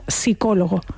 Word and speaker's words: psicólogo: La palabra psicólogo se escribe con una P inicial, pero esa P no la pronunciamos. – Es verdad, es verdad. – Decimos psicólogo, no decimos psicólogo.